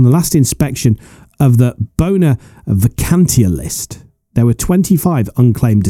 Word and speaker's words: Bona Vacantia: It is a mystery to many people still, including the pronunciation of Bona Vacantia, which you handled very professionally.